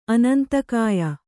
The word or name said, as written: ಅನಂತಕಾಯ